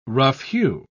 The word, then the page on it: rough-hew: British English